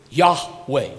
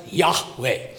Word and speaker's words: Yahweh: The agreement is the YHWH, the agreement, the consensus is Yahweh. We've added the vowels, okay? They weren't existing in the original Masoretic text. But it's a consensus with the Judeo-Christian community. It is pronounced Yahweh.